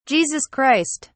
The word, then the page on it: Jesus Christ: British English